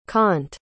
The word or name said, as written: Kant